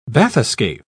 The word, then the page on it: bathyscaph: British English